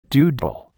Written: doodle

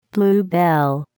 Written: bluebell

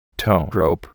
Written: towrope

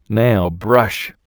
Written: nailbrush

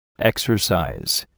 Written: exercise

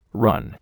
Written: run

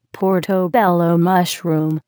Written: portobello mushroom

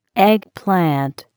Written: eggplant

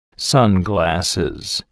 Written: sunglasses

